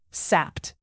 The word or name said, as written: sapped